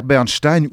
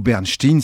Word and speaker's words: Bernstein: signé Elmer Bernstein ou Bernstein, ça dépend comment on le prononce, soit à l'américaine, soit à l'européenne.